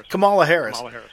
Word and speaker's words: Kamala Harris: Was it Kamala? I cannot say her name. – Kamala Harris.